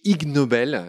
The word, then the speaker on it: Ig Nobel: En 2004 a été attribué le prix Ig Nobel, enfin qui joue sur le jeu de mots euh, ignoble, qui s'écrit IG plus loin Nobel,